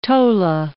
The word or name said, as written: tola